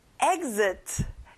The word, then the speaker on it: exit: 출구, exit. 여러분, X를 Z 발음으로 해보세요. Exit.